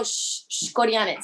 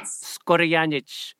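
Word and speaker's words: Škorjanec: Am I pronouncing that correctly? – Skorjanec is the tough one. – Ah, Skorjanec. Okay. – You have to be like you have to go Skorjanec. – Skorjanec. – Pretty good. Yeah.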